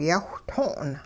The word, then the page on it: yawhtong': hupa